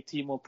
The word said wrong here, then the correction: Teemu: And also, it's Teemu. So if you want to practice it, go ahead.